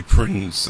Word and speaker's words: Prinze: I say Prinze, that's the way he pronounced his name.